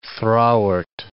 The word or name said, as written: thrawart